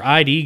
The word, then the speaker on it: id: It's just pronounced id. – Whatever. Is it id? – Yeah.